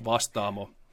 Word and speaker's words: Vastaamo: Yeah, you actually did a did a pretty good job of it. It's it's pronounced Vastaamo, okay.